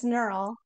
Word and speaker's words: Knerl: No, it's Knerl. – Knerl. Okay. I honestly thought so, but I was just like, I don't know, silent K.